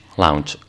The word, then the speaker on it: lounge: Y Pijuán era el creador de la salsa lunch, que nunca sé cómo pronunciar, lunch o lounge. – Lounge. – Lounge, vale. Lounge, me quedo en lounge.